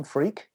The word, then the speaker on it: Freke: So I'm delighted to say, if you just put in Tim Freak, as long as you spell it F-R-E-K-E, it's a weird name, but there you go.